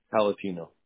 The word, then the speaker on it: Pallottino: Palatino. – Will you spell that for me? – P-A-L-L-O-T-T-I-N-O.